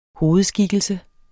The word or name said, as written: hovedskikkelse